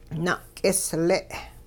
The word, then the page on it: na'k'isle': Hupa